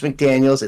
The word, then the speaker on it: McDaniel: How do you say that? It's Josh McDaniels. It's Mike McDaniel.